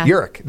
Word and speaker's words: Urich: Urich. – Thank you. Yes, Urich.